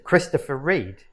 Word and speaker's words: Christopher Reid: Because we're going to be doing a promotional event for one of our new residents who goes by the name of Mr. Christopher Reid.